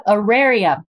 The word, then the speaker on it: Auraria: You're the chief of at, how do you say the higher education center, Ariana? – Yep, Auraria. – Auraria, I didn't say it at all right. Doesn't surprise me because you always rise up to that level of mentor, leader, chief collaborator.